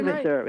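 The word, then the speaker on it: Invasor: You know, you didn't get it wrong. It's been pronounced three different ways, but it's I-N as a nomin, V-A-S-O-R. So Invaser, you can pronounce it that way and be correct.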